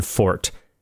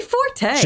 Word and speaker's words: Forte: Fort, who, by the way, Alan, your name is spelled Forte. – Yes, say Forte. – Just be Alan Forte.